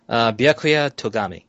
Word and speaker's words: Byakuya Togami: Byakuya, yeah. But all right, pronounce that one more time. – Byakuya Togami.